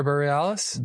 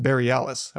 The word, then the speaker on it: Bareilles: It's created by Sarah Bareilles. I'm probably mispronouncing her name, but she's an incredible Bareilles? – Writer. What is it? – Oh yeah. Sarah Bareilles. – Bareilles.